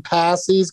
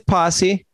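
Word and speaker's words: Pasi: Adam Passy's going to, am I saying it right? Passy? – It's Posse. Yeah, Adam Posse. Posse. Yeah. – Oh, God, I wish he spelled it P-O-S-S-E, but it's P-A-S-I.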